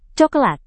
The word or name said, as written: cokelat